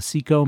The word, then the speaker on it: CECO: The band we just heard was Merle Johnston and his Seco Couriers, and they were sponsored on radio by the Seco Manufacturing Company of Providence, Rhode Island. Seco is spelled C-E-C-O with both of the C's capitalized.